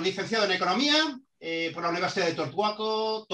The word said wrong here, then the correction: Torcuato: Torcuato de eh, Ditea, creo que lo he dicho bien, italiano, ¿verdad?